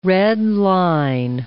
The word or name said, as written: redline